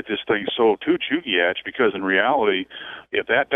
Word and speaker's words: Chugach: No, it's Chugach. Okay. Now, there's a village just north of Anchorage called Chugach. – Yep. – Three syllables.